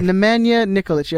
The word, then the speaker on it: Nemanja Nikolic: Nemanja Nikolic, yeah, that's how you say it.